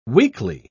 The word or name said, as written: weakly